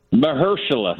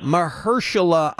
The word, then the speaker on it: Mahershala: Marshala Ali, is that how you pronounce his first name? – Mahershala. – Mahershala Ali.